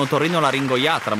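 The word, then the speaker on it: otorinolaringoiatra: Diego si dovrà far visitare, dovrà andare da un otorrino laringoiatra molto presto, andando avanti così. – Bravo, l'hai detta tutta, una parola difficile da pronunciare senza errori, eh.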